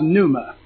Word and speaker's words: pneuma: but it looks like it's pronounced pneuma, and it's got a P in there, and I don't know why the P is silent, but now I know why pneumonia has a P.